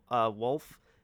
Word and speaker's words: Wulff: Some genius on YouTube, and I don't mean that sarcastically, some literal genius on YouTube, his name is Bob Wolf, but it's spelled W-U-L-F-F.